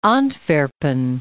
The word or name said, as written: Antwerpen